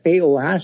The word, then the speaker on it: POH: POH, zeg ik dat goed? Ja.